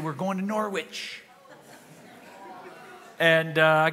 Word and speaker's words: Norwich: And so, of course, it's Norwich. And I've learned the only way I can remember it is that Norwich rhymes with porridge.